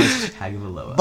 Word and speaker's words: Tagovailoa: Tua Tagovailoa. I don't know if I pronounced that right. – Tagovailoa.